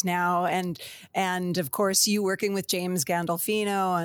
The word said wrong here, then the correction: Gandolfini: and have I said it right? Gandolfini? – Gandolfini. Yeah, you almost Gandolfini. I knew it was wrong as soon as I said it.